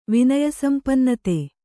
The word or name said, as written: ವಿನಯಸಂಪನ್ನತೆ